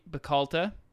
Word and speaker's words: Bacalta: Our man beverage today was Glenmorangie Bacalta. Is that right? – Yes. No, you said it right.